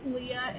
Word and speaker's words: Leah: My name is Leah, and it's spelled L is in Lima, E is in Echo, A is in Alpha, H is in Hotel.